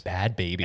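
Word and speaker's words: Bhad Bhabie: Bad Baby. – And baby is spelled, it's like B-H-A-B-I-E. – Bad is spelled B-H-A-D.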